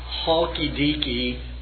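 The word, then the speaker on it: Chalkidiki: And even though it's spelled like chalk, C-H-A-L-K, I think it's pronounced halky-deaky, halky-deaky peninsula, which is, of course, is very like the immediate environments of Thessalonica itself.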